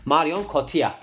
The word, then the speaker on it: Marion Cotillard: Telaffuzu nasıl ediliyormuş diye. Marion Cotillard diye telaffuzu.